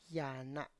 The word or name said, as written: ya na